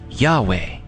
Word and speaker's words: Yahweh: Yah is shortened from the four-letter Hebrew name for the Lord, YHWH, which is usually pronounced Yahweh.